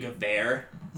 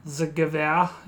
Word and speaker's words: Gewehr: But the fucking, I call it the Gruer, but I guess I pronounced it wrong all this time. I guess it's the Gewehr. – The Gewehr, yeah.